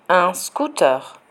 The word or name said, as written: un scooter